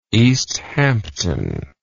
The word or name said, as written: Easthampton